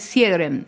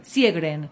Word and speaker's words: Sjögren: El mensaje de Paula de Coquimbito dice, buen día chicas, ¿cuándo van a hablar del, a ver, no sé si lo pronunció bien, Sjögren? – El síndrome Sjögren. – Sjögren. – Sí.